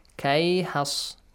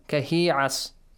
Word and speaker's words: كهيعص: You can't join them. For example, you can say كيحص. No. كهيعص. You can't say.